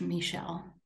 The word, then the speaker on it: Michel: Michael is spelled like the French Michel.